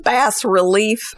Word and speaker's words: bas-relief: Bas-relief. – So, real quick, you're both wrong. It's bas-relief. It's short for basso relievo, is like the full name.